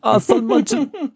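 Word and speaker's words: Sandmännchen: Sandmannchen. – Wait, what? Is it German or Asian? – It's German. – All right, spell it. – S-A-N-D-M-A-N-N-C-H-E-N. – M-A-N-N-C-H-E-N? – C-H-E-N, yep.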